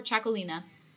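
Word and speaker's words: Txakolina: So there's Chacolí or Chacolina, and that's spelled TXAKOLI and then NA.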